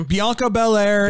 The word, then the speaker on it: Bianca Belair: Bianca Belair, is that how you say her name? – Bianca Belair, and Becky Lynch lived up to every single freaking thing that you could ever want out of a women's title match.